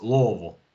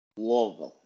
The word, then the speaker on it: Louisville: Oh, it's definitely Louisville and not Louisville. – It's Louisville. – Louisville. – Yeah, no Louis, Louisville. – Louisville. – Okay. Yep. That's how they say it down south, at least.